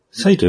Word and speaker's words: PSI: サイコキネシスですね。 PK です。マザーというゲームの魔法に当たる超能力で、攻撃系の際、 PSI と呼んでる人もいますが、サイと読むんですよね。